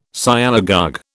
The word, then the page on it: Sialogogue: British English